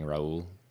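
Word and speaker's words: Raúl: So in December 2014, President Obama and Raul Castro, am I pronouncing that correctly, Danny? Raul? – Yes, that's great.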